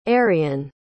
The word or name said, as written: arian